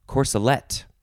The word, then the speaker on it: corselet: And for definition two, it will be corselet. It is spelled like the first word, C-O-R-S-E plus L-E-T, corselet.